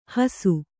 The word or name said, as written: ಹೂಸು